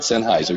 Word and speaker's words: Sennheiser: But is it Sennheiser? That microphone. – Good enough for us. – Yeah. – Sennheiser, I think, is what it is. – Yes, that's it. Sennheiser, yeah.